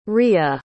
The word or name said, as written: rya